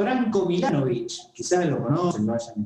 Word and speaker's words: Milanovic: Sí, Milanovic, la C final se pronuncia Che.